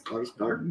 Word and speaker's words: Skarsgård: Skarsgård, am I saying that right? – Skarsgård. – Yeah, that is correct.